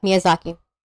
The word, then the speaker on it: Miyazaki: Miyazaki. Is that how you say it? Make sure I'm saying it properly. – Yeah, Miyazaki.